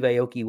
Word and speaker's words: Aoki: I didn't even know who Steve Aoki was. – That's okay. – Am I saying it right? – Yes. – Aoki? Aoki? – Yeah, you're good.